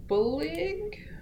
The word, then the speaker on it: Firbolg: Firbolg? It looks Yes. – like Firbolg, but I know that's not correct. They were defeated in a great battle by the Dedannen. – Firbolg.